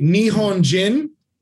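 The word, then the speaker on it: Nihonjin (日本人): The Nihon Jin which, by the way, I know I butchered that, but Nihonjin, that's the name of the tribe in the movie.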